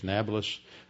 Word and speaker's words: Nablus: Neapolis, Arabs in Arabic, they can't pronounce a P, so it's changed to a B, so you get Neapolis or Nablus, meaning new city.